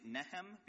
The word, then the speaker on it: Nihm: I'm going to be using the more common translation used by scholars, which is N-I-H-M, and pronouncing it Nehem, because that's how I understand locals tend to pronounce the name in Yemen.